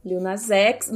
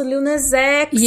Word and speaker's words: Lil Nas X: Como que pronuncia? Lil Nas X, é isso? – Lil Nas X. Nossa, Lil Nas X!